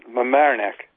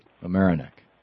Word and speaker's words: Mamaroneck: Mamaroneck. – Thank you. Mamaroneck.